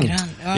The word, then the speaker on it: Grande: Hun jo til italiensk opphav, så det ikke feil å si Ariana Grande. – Mm. – Men hun selv sier selv at hun gjerne vil uttale det Grande. – Oh, ja. – Så okay. Ariana Grande. – Åh, oh, ok, mm-hmm. grann.